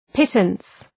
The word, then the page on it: pittance: ‘pıtəns